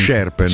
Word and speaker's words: Scherpen: e allora eh, Ten Hag se la sta giocando con eh, Sherpen o Scherpen come si dice? tu che sei un esperto di pronunce storicamente. Sherpen.